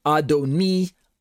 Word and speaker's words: Adoni: And they, they are definitely pronounced differently if you can hear them, Adoni, Adonai.